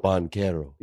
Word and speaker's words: Banchero: I'm going to give you a 9 out of 10 because you mispronounced Banchero's last name, but you did it in a way a lot of people do. You did the ch and it's k. So Banchero.